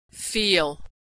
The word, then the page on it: feel: American English